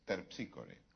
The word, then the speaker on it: Terpsícore: Dije, es Terpsícore. – Ah, claro. Es Terpsícore. Es Terpsícore. Esa es la pronunciación griega, claro.